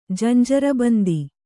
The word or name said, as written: ಜಂಜರಬಂದಿ